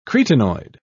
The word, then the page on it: cretinoid: British English